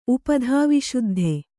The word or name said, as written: ಉಪಧಾವಿಶುದ್ಧ